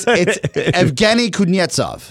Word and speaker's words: Evgeny Kuznetsov: It's Evgeny Kuznetsov.